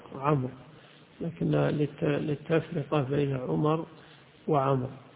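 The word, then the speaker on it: عمرو: الواو تكتب ولا تنطق، تنطق عمر، إشارة للتفرقة بينه وبين عمر، فهي اختلاف الحركات فقط، وإلا كل منهما ليس فيه واو، عمر وعمر كلها راء ساكنة يوقف عليها بالراء الساكنة، لكن الواو للتفرقة بين عمر وعمر.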